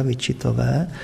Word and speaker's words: Wichitové: tak se to teda vyslovuje dneska Wichitové, takže bych se mohl jmenovat Jan Vyčita.